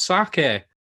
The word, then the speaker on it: sake: But I didn't know mine, and gets to Christmas Day, open it up, and it's a bottle of sake. Is that how you say it? – Yeah, it's close enough.